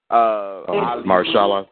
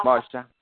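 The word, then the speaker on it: Mahershala: Marshalla. – Marshalla.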